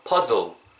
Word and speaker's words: puzzle: 이게 즈 발음이거든요. 그래서 puzzle, puzzle 다르죠? – 퍼즐, 퍼즐, puzzle, puzzle 다르죠?